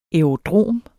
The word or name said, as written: aerodrom